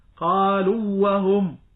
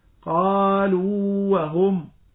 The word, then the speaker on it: قالوا وهم: وكذلك في الواو، قالوا وهم، الواو هنا من قالوا واو مدية، وبعدها وهم الواو المحرك، فإذا أسرعت في النطق قلت قالوا وهم فأدغم، والإدغام هنا ممتنع لئلا يزول حرف المد، بل يجب عليك أيها القارئ أن تمكن النطق بحرف المد، فتقرأ قالوا وهم قالوا وهم.